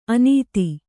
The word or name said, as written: ಅನತಿ